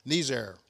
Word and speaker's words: Niger: Those in the know use the French sounding Niger for the word for that Hebrew word, and emphasize the second syllable so it makes the approximate rhyme with Pierre, Niger.